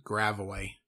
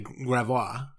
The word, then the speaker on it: Gravois: And it's, I mean, it's kind of the karma for people in St. Louis calling it Gravoy. – Yeah. That's what it is. When it's actually, when it's actually Gravois.